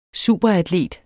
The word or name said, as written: superatlet